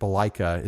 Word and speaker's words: balalaika: And they had these like crazy red hairdos, and the bass player in the band plays a giant electric balalaika. Is that how you say it? – Close enough.